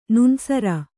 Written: ನೋಣಿಸರ

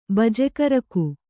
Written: ಬಜೆಕರುಕು

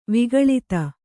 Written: ವಿಕಳತೆ

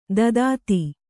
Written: ದದಾತಿ

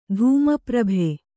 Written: ತಮಪ್ರಭೆ

